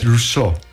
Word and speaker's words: Rousseau: Es que lo pronunciamos mal, es Rousseau.